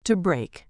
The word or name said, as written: to brake